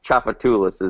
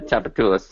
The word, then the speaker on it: Tchoupitoulas: Yes, we have a lot of Indian words, like Chapatulas is the name of a street. That when you see it, you will go, how do you pronounce that? Like T-C-H... O-U-P-I-T-O-U-L-A-S.